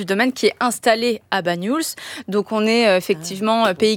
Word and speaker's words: Banyuls: Comment on le prononce? – Banyuls. Oui, ouais. C'est ça.